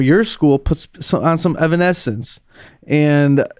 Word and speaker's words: Evanescence: It's an Evan. Essence. – Evanescence.